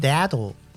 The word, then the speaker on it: dato: 呃，我都是笔名都是用 D A T O，可是写 D A T O，大家就问我说：“ 请问这个怎么念呢？” – Dado。 – 对，那就是中文的大头了。